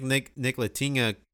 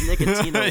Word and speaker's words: Ntilikina: I mean, maybe Frank Ntilikina. Why do you pronounce his name again? Ntilikina. Ntilikina. – I think you pronounced it as Nicotina one time.